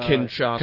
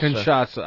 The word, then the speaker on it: Kinshasa: Uh, he hit what three sh- uh, Kinshasa. Kinshasa.